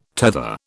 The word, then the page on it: tether: British English